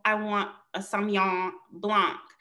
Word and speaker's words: Sauvignon Blanc: Is that the correct pronunciation? – Yes, Sauvignon Blanc.